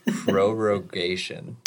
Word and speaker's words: prorogation: The term is prorogation. – Nailed it. Brilliant. Somewhere Daniel Webster's smiling down on you. – So apparently this is the longest prorogation since like the 20s.